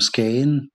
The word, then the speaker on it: Skagen: Also die dänische Aussprache ist eben Skagen. Da werden oft Buchstaben so ein bisschen verschluckt oder halt nicht ausgesprochen wie das G. Ja, das ist dann eben weg.